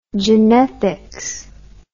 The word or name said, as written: genethics